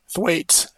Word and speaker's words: Thwaites: And how do you pronounce that glacier? Thwaites. Thwaites. Thwaites? Okay.